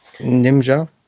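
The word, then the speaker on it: Njinmah: Ähm, da gibt es ja doch im Angriff einen neuen Spieler, der Justin, wie wird er ausgesprochen? – Nimja. Nimja. Nimja. Ja.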